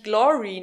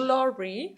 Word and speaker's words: Glore: Bei der Eröffnung von einem neuen nachhaltigen Klamottenstore hier in Frankfurt auf dem Öder Weg, der sich Glory nennt. Ich dachte immer, es wird Glor ausgesprochen, also G-L-O-R-E, aber es ist für Globally Responsible, also Fashion. – Glory.